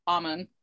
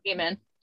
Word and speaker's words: Amun: And even though initially I know that was uh, Amun's penis is Amun or Amen, as the many pronunciations are.